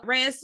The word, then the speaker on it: Raya: So Raya's sword was did I say it wrong again? – Raya. – Raya.